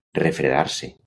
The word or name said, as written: refredar-se